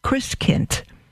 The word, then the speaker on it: Christkind: Michael Matt writes, It all began in Advent when my seven sisters and brothers were expected to prepare for the coming of Christkind, pronounced Chriskind.